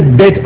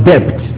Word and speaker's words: debt: Do you say debt or debt? Which one do you prefer? Debt, not debt. They say the B is silent.